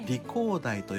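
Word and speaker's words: 梨香台: で、さっきあの、ね、メタル冠動脈さん、千葉県松戸市の、はい、なしが香る台と書いて、理工台と読みますというふうに教えていただきました。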